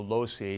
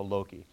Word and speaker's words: Loci: And if you're church Latin, you'll call it the Loci, and if you're classically trained Latin, you call it the Loci, because the hard C is classical and the soft C is church Latin, so that's the difference there.